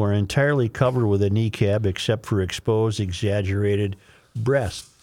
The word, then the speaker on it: niqab: And some graphite drawings show the bodies of Muslim women wearing hijabs or niqabs, clothing that is usually meant to preserve modesty.